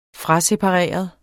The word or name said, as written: frasepareret